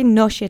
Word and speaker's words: nosie: The S followed by an I becomes a sh sound. Remember that. Let's say nosie together three times. Accent is on the first syllable.